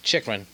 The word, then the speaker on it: Chychrun: Chychrun.